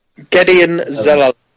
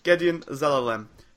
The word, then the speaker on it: Gedeon Zelalem: I'm hoping it is, and I can't say his name, the American lad from Arsenal, Zaralemelem. – Zaralemelem? – Gedeon Zelalem. – Gedeon Zelalem.